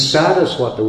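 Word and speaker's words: Sārasvata: Because it's not Saraswati Devi, as people sing sometimes. It's Saraswata with a long A in the beginning.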